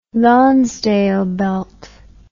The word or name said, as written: Lonsdale belt